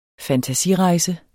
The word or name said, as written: fantasirejse